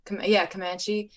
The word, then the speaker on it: Comanche: Pronounced, Comanche.